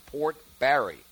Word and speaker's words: Barre: Port Barry, even though it's spelled B-A-R-R-E. Port Barry.